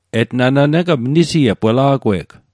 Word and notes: etnana: Mi'gmaq

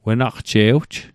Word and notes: wenaqje'wj: Nova Scotia

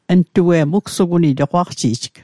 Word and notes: ugsuguni: Mi'gmaq

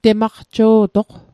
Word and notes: temaqjo'toq: Mi'gmaq

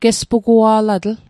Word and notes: gespugua'latl: Mi'gmaq

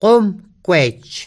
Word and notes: gomgwej: komkwej; Mi'gmaq

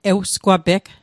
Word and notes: ewsgwapeg: Mi'gmaq

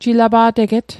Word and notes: jilapa'teget: Mi'gmaq

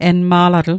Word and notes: enma'latl: Mi'gmaq

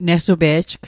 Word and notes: nesupe'j: Mi'gmaq

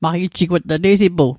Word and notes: maqijjigwat: Mi'gmaq

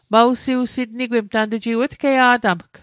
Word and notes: sitnigwit: Mi'gmaq

